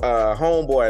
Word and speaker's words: Homebwoi: So homeboy, and I spell it HomebwoI.